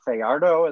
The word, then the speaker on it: Fajardo: But you know, they went down early on a Fajardo. Is that how one would pronounce that? That's how it looks yes. – to be pronounced. Yes. Goal in really sloppy situation,